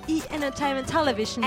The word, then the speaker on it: E! Entertainment Television: E! Entertainment Television.